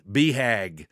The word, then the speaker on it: BHAG: BHAG. Man, this is a BHAG. BHAG is spelled B-H-A-G.